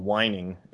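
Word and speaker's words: wining: And there's a dance called it's just called whining. It's like winding it up, but it's just pronounced whining.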